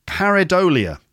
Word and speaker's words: pareidolia: Pareidolia.